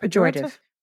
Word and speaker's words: pejorative: Like the name is pejorator. How do you say that? – Pejorative. – Pejorative, but it's still a cute name.